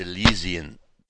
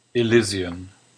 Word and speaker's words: Elysian: Hey, it's not Elysian. Elysian is something bad on your skin. It's Elysian. – Elysian. – There you go.